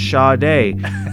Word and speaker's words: Sade: this last track was by Sade, which I also thought that was how you pronounced it, by the way, like years ago until somebody corrected me. Well, somebody called in the studio after his mic break, and he answered the phone, and they said, hey, it's actually pronounced Sade.